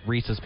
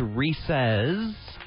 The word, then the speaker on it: Reese's: You and I disagreed on that one, but we both agree on the correct way to say Reese's. – It's Reese's Peanut Butter Cups or Reese's Pieces. – As opposed to, what are the other people saying? – Reese's. – Incorrect. – It's the incorrect way to say things.